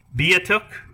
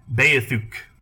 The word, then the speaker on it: Beothuk: All right, so according to Wikipedia, this is either pronounced Beatuk or Beathuk.